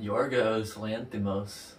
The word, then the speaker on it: Yorgos Lanthimos: Yorgos Lanthimos.